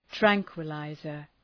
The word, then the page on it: tranquillizer: ‘træŋkwə,laızər